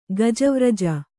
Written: ಗಜವ್ರಜ

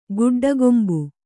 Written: ಗುಡ್ಡಗೊಂಬು

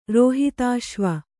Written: ರೋಹಿತಾಶ್ವ

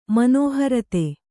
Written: ಮನೋಹರತೆ

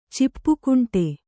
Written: ಚಿಪ್ಪುಕುಂಟೆ

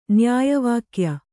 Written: ನ್ಯಾಯವಾಕ್ಯ

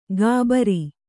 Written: ಘಾಬರಿ

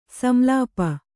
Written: ಸಂಲಾಪ

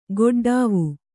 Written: ಕಟ್ಟಡವಿ

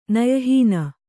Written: ನಯಹೀನ

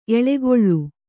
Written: ಎಳಕುಳಿ

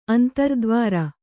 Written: ಅಂತರ್ದ್ವಾರ

